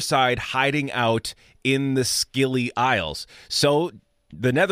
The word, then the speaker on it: Scilly: Isles of S-C-I. Yeah, Isles of Scilly, S-C-I-L-L-Y. I saw that and I thought Sicily and I thought Italy. Well, maybe not quite. They're over by Cornwall, England, basically. I think it might be pronounced Scilly, and it's a little bit silly.